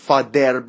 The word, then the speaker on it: father: حالا این بین د و ز تلفظ میشه و، و. TH رو هر جایی که تلفظ میکردین دندون عقبتر از نوک زبون قرار میگرفت، این به نوک زبون نزدیکتر هستش. father، father، father.